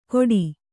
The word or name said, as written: ಕೊಡಿ